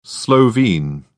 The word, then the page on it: Slovene: British English